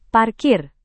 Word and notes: parkir: Indonesian

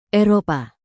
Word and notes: eropa: indonesian

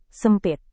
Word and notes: sempit: indonesian

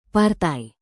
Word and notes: partai: Indonesian

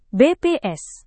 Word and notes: bps: Indonesian

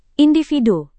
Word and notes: individu: indonesian

